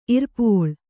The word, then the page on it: ಇರ್ಪೋಳ್: Kannada